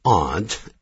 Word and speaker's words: aunt: 但是呢如果我是阿姨来了， My aunt is coming。可是到了美国的时候呢， A-U-N-T aunt，很多人还是念成 aunt。 My aunt is not an ant，我阿姨可不是蚂蚁，是这两个字的发音呢很多美国人是搞不清楚的。